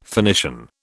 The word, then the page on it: Phoenician: British English